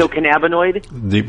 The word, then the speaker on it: endocannabinoid: What's it, endopamide? – The endocannabinoid? – Right, okay.